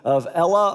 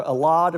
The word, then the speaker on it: Elah: This particular valley is the valley of Elah or Allah, depending on how you want to pronounce that.